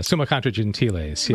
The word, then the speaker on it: Summa Contra Gentiles: The Contra Gentiles? Is that how you say it? – Summa Contra Gentiles. Yes, right.